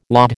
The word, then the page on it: laud: American English